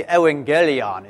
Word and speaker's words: evangelion: Yeah, proto in, in uh, you, would, you would say evangelion if you were pronouncing it in Greek, but proto, the proto evangel, the, the proto good news, because evangel is, you know, the, the good news.